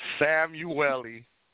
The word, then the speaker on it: Samuélli: Samuélli.